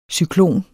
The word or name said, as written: cyklon